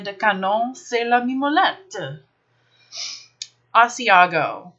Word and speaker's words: Asiago: Let's look at how this word is spelled. The next cheese is Asiago. A-S-I-A-G-O. There is an I before the second A in Asiago. Ignoring that fact, a lot of people come in looking for Asagio.